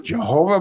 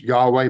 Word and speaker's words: YHWH: Pronounced Jehovah by some, pronounced Yahweh by others.